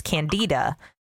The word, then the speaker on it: Candida: No, I don't know why that's what I was taught, but the American English pronunciation is Candida. I have never heard anything other than Candida, and it is what I will say, but both are correct.